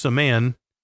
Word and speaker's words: Saman: I kept calling him Josh Salmon, and at the very end of the interview, he's like, by the way, it's a man.